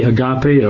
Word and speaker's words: agape: And love in this sense, in the Greek, is the word agape, A-G-A-P-E, agape or agape. I think agape is the normal pronunciation.